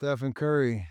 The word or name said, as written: Stephen Curry